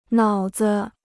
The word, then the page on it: 脑子: nǎo zi